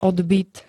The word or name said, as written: odbyt